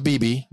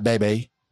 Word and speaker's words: Bebe: And it is pronounced BB, not Bebe or whatever.